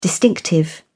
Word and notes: distinctive: British English

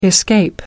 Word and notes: escape: American English

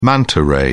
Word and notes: manta ray: British English

